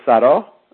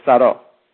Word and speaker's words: sarò: io sarò. Notice the accent on the O gives it more emphasis on that last syllable. Io sarò.